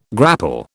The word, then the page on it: grapple: British English